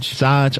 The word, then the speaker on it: Saj: So the next song that I do want to get into is Can't Twerk, and that one is by S-A-J. Is that the name, or Saj? – Saj.